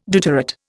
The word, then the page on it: deuterate: Indian